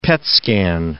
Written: PET scan

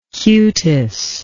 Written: cutis

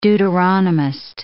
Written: Deuteronomist

